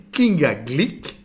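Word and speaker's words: Kinga Glyk: Nous venons d'écouter donc une sélection de Philippe, d'une musicienne polonaise, Kinga Glik, ce coup-là je n'écorche pas son nom, qui vient de nous faire entendre Difficult Choices.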